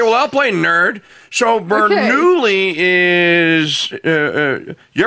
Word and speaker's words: Bernoulli: She was doing Bernoulli. – That's how you say it.